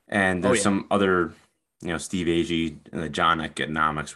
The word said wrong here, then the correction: Economos: whatever the heck you pronounce it. – I think it's Economos.